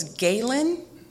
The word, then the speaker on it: Gehlen: My maiden name was Galen. G-E-H-L-E-N, pronounced G-A-Y-L-A-N.